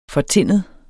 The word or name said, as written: fortinnet